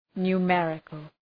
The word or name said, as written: numerical